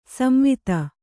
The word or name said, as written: ಸಂವಾದ